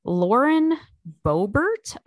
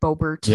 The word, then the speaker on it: Boebert: Lauren Boebert, I think, or I don't know how else you would pronounce that. Boebert.